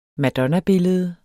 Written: madonnabillede

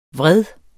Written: vred

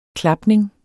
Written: klapning

